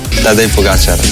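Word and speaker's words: Tadej Pogačar: Juanma dice que es aguda, mientras que otros es drújula, así que aquí tenemos cómo se dice de verdad. – Tadej Pogacar.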